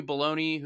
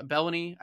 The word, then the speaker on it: Belloni: And and uh, Matthew Belloni, who uh, Bellany, I actually think is how you say it.